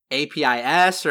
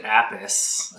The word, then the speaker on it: Apis: SweetBile has invented a patch, and I'm going to do my best to describe this patch, but it's I think it's named APIS, or how do you pronounce it? – Yes, so it's named Apis, or Apis, off of the Apis mellifera, which is Latin for honeybee.